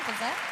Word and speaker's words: Beuzet: Yeah, Beuzet.